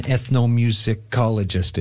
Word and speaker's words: ethnomusicologist: Remind folks what an ethnomusicologist is, if I said that right. – Yeah, you got it.